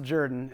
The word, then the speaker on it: Jordan: It's actually spelled Jordan, but it's pronounced Jordan. It's a Deep South thing.